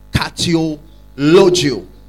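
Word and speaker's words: kateulogeo: And the third word that has been translated blessing also in the Bible is what is called catalogio. Catalogio means to bless fervently. It is spelled K-A-T-E-U. K-A-T-E-U. L-O-G-E-O. K-A-T-E-U. L-O-G-E-O. Catiologio.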